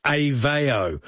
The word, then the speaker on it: Aveo: Yes, it's called Aveo. That's how it's pronounced, Aveo.